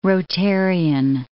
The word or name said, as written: Rotarian